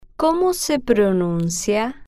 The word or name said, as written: como se pronuncia